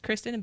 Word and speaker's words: Kristen: K-R-E-E-S-T-I-N? It's kind of like my name is Kristen, but you say it like Kreestin, like that.